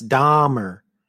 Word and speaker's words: Dahmer: But it's pronounced Dahmer. – No, it's Dahmer. – Yes, Dahmer. That's how it should sound.